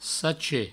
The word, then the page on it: sacher: sache